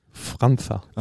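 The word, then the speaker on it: França: Franza. – Ach krass. Das kommt von deinen portugiesischen Genen, ne? – Ja, genau. Das ist das C mit dem Haken drunter. Das spricht man aus.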